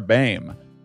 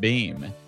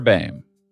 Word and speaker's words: Baim: Throughout the episode, I pronounced her name incorrectly because I went to high school with someone who spelt their name the exact same way and they pronounced it Beam. I thought that was a universal thing.